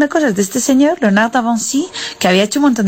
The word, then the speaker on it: Léonard de Vinci: Pues resulta que me puse a mirar en el libro y era Leonardo da Vinci pronunciado en francés. Yo no me he enterado. Un abrazo de la farada sin martes de Catalu. – Léonard de Vinci.